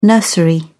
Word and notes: nursery: British English

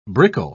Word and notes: brickle: British English

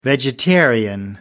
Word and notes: vegetarian: American English

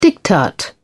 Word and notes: diktat: British English

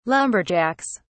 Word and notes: lumberjacks: British English

